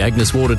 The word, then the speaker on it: Agnes Water: Down to Agnes Waters. – Mate, it's pronounced Agnes Water.